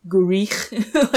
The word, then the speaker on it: Gwrych: So it's G-W-R-Y-C-H. And when I looked up how to pronounce it, they recommended you say Gwrych, but...